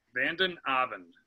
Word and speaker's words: Vanden Avond: Did I say that right? Tell me how to say it properly. – Vanden Avond. – Matt Vanden Avond. Yep. And you said that is Dutch. – Yes.